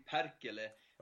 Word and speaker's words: Perkele: In Swedish, you say Perkele.